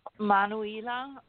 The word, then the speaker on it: Manuela: Let's go with 9-2-1-1. – 9-2-1-1. Your mic is live. – I'm Manuela. – Hello. How are you? – I'm good. – How you say your name again? – Manuela. It is M-A-N-U-E-L-A. Manuela. – Okay. – M-A-N-U-E-L-A.